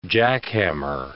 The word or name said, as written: jackhammer